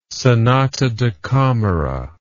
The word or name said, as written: sonata da camera